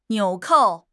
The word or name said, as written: 纽扣